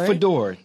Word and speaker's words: Fodor: Is that how you say your last name? – Fodor, who was so nice in setting this interview up.